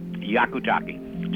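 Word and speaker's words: Hyakutake: Hayakutake. Just drop the H. – Hayakutake. – Just drop the H. Forget there's an H on it and you're all right. – Hayakutake.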